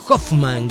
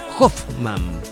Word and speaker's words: Hoffmann: se pronuncia Felix Hoffmann con F, Hoffmann, ahí está, Felix Hoffmann, y se escribe H-O-F-F-M-A-N-N, Bueno, ahí está.